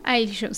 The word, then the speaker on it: Aiyu: espacio podamos charlar un poco de Aiyu. Lo pronuncié medio porteño, ¿no? Aiyu. – Aiyu, sí, es Aiyu.